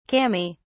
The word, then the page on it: kami: British English